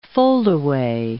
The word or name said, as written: foldaway